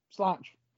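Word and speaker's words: Sláinte: It's spelled out, though, for the Americanized version. It's Solante, but it's Solange.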